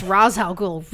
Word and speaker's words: Ra's al Ghul: The asshat who created Ra's al Ghul say it's Ra's al Ghul really, really, really pisses me off.